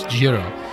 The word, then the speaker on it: Jiro: All right, the title of the film is Yero's Dreams of My Sushi. – All right, I gotta stop both of you guys. You're giving it some like weird Latin pronunciation. He's Japanese. It's Jiro.